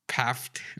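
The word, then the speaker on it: Pfaadt: It's P F A A D T. I think it's like Paft or something.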